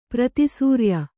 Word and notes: ಪ್ರತಿಸೂರ್ಯ: prati sūrya

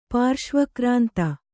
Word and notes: ಪಾರ್ಶ್ವಕ್ರಾಂತ: pārśva krānta

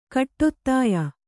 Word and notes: ಕಟ್ಟೊತ್ತಾಯ: Kannada

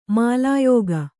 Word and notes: ಮಾಲಾಯೋಗ: mālāyōga

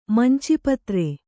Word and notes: ಮಂಚಿಪತ್ರೆ: manci patre

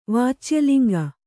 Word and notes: ವಾಚ್ಯಲಿಂಗ: Kannada

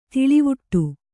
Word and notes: ದೂಳುವಡೆ: Kannada

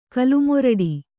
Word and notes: ಕಲ್ಮೊರಡಿ: kalmoraḍi

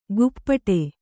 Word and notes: ಗುಪ್ಪಟೆ: Kannada

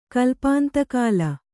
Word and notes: ಕಲ್ಪಾಂತಕಾಲ: Kannada

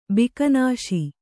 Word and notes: ಬಿಕನಾಸಿ: bikanāsi

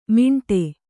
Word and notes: ಮಾಂಟೆ: māṇṭe

